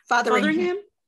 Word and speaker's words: Fotheringham: Did I say that right? – Fotheringham.